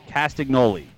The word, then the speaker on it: Castagnoli: I don't know how you pronounce his last name. – Castagnoli. – Castagnoli. – Yeah, Claudio Castagnoli.